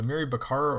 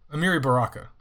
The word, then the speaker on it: Amiri Baraka: Is it Amiri Baccara or Amiri Baraka? Amiri Baraka. Yeah, Amiri Baraka.